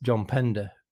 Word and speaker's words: Pender: I'm going to go with John Pender because, well, apart from the fact that his name rhymes with defender, which is reason enough to pick him, Steve Davis is not the player he was without John Pender stood next to him.